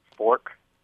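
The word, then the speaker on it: Forque: The one that's kind of leading is a restaurant that would be called Fork, but spelled like torque, as in like the torque on an auto engine. – Q-U? – Yep, Q-U-E.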